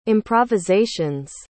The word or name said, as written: improvisation's